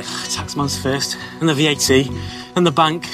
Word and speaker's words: VAT: Z innych skrótów, które często stosujemy, nawet po polsku, mamy VAT. Po polsku używamy tego skrótowca jako akronimu, czyli czytamy to, jakby było oddzielnym słowem. Po angielsku wymawiamy każdą literę oddzielnie. VAT.